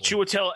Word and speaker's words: Chiwetel: Yeah, Chitula. Yeah, or I can never say his name properly, but Ejiofor. – Chiwetel Ejiofor.